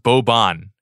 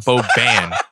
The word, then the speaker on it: Boban: First of all, it's pronounced Boban. It's not Boban.